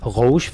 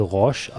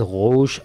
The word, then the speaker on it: Roche: Roche wird das ausgesprochen. Ich habe es französisch gesagt, Roche, aber es wird Roche ausgesprochen.